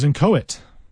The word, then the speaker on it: inchoate: It's spelled I-N-C-H-O-A-T-E. I-N-C-H-O-A-T-E, inchoate. I suppose it's somewhat phonetic, but you kind of want to pronounce it inchoate because there's that C-H, inchoate.